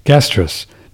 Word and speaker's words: Gastrus: Is this what it's called, gastritis? No, gastritis is gastrus. No, G-A-S-T-R-U-S. Sorry, I was mispronouncing it again. Gastrus.